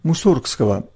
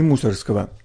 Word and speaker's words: Мусоргского: Я не оговорился, поставив ударение в фамилии русского композитора именно так – Мусоргского, а не Мусоргского. В одной из наших передач была аргументация в пользу такого произношения. В самом деле, ведь не от слова же «мусор» произошла фамилия этого музыканта.